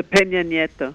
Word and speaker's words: Peña Nieto: There seems to be enormous enthusiasm, and there's got to be a change, a demonstrative change from the Peño. I keep doing that. I am not pronouncing it correctly. Help me. – The Peña Nieto.